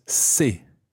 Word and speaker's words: ces: And the plural version is c'est. C-E-S. C'est. C'est. Now be careful not to confuse c'est, C-E-S, with se, which sounds identical but is spelled S-E-S.